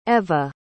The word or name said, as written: Ewa